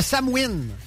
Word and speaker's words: Samhain: Ça se prononce le Samhain. – Samhain? – Le Samhain. Je pensais que ça se prononçait le Samhain, parce que c'est de même que ça s'écrit. Mais ça s'écrit S-A-M-H-A-I-N.